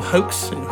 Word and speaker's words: Haux: And he goes under the moniker, I think it's pronounced Hawks, H-A-U-X, or Hoax, if you're in France.